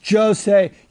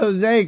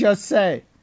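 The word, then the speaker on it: José: It's Jose. You don't even know fights. You said Jose because it's spelled Jose. It's Jose. Because he's Brazilian, you asshole.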